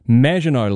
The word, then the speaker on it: Maginot: Maginot line, not Maginot line as you pronounce it.